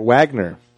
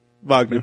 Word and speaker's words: Wagner: By Wagner. – Mm-hmm. – Or Wagner, depending on, Wagner. – New pronunciation. – Mm-hmm. – It's like you start off with a V, end up with a W.